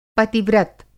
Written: पति-व्रत

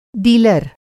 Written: डीलर

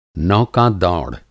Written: नौका-दौड़

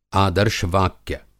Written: आदर्श वाक्य

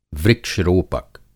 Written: वृक्ष-रोपक

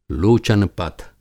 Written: लोचन पथ

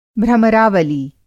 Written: भ्रमरावली

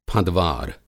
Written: फँदवार

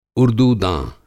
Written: उर्दूदाँ

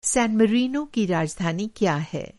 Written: सैन मैरिनो

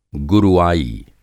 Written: गुरुआई

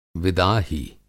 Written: विदाही

